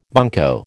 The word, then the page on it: bunco: American English